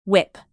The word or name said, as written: whip